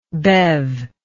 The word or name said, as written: bev